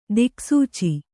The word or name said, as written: ದಿಕ್ಸೂಚಿ